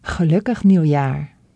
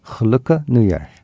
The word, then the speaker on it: Gelukkig nieuwjaar: Gelukkig nieuwjaar. – Gelukkig nieuwjaar.